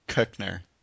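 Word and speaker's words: Koechner: And you're not saying it right. You have to put your tongue at the top of your mouth. Kochner.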